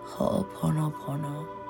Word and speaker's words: Ho'oponopono: Something about the frequency of those phrases as you're meditating, and you can do Ho'oponopono, and it's spelled H-O apostrophe O-P-O-N-O P-O-N-O Ho'oponopono.